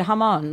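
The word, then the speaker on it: jamón: Amon, I didn't even say that right. – No, you did. You did. Hamon. Like Hamon. Yeah, I said it wrong.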